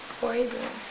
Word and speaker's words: Boriboune: Yeah, it's B-O-R-I-B-O-U-N-E. – B-O-R-I-B-O-U-N-E. Bori Boone. And Noi is N-O-Y.